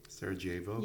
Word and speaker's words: Sarajevo: She also really couldn't say Sarajevo. She had always pronounced the J. – Sarajevo? – Yeah.